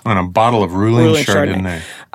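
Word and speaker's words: Reuling: Ruling Chardonnay, that is probably one of the finest Chardonnays in Sonoma.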